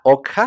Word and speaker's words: Okja: Eh, en Netflix él tiene una película que se llama Ugya, se escribe O, K de kilo, J-A, Okja.